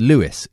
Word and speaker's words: Louis: It's spelled Louis, but pronounced Lewis here in the States. Justin inadvertently used the French pronunciation of St. Louis.